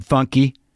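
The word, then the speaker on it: Fonky: It's how it's spelled, F-O-N, but funky.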